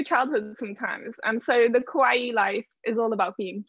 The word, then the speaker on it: kawaii: Actually, it's kawaii. – Kawaii. I thought I was pronouncing kawaii. Kawaii. So the two I's are an E. Just imagine it as an E.